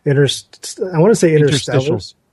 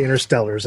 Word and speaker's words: interstitials: And they don't happen just on mobile sites, and it's not just for the ones that I can never pronounce this word. – Interstitials. – Interstitials. That's it. I keep wanting to say interstellars, and I know that's not right.